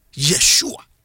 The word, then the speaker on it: Yeshua: Na Hebrew fo no omo eni letter J. Enti nia ye je bi omo pronounce no. Ye Yeshua. Yeshua.